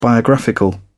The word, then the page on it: biographical: British English